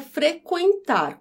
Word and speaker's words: frequentar: Então, por exemplo, a palavra frequentar, como era, aí com trema na letra U deixou de ter o trema. Frequentar. Note que eu, pronuncio, eu pronuncio da mesma maneira.